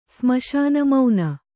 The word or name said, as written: ಸ್ಮಶಾನಮೌನ